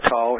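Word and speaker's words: tall (ת): And a tall is that T, it's pronounced like a, just like a T.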